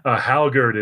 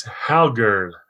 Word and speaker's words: Hallgerd: Hallgerd is Hallgerd. And I think it's Hallgerd. Hallgerd, almost a TH at the end.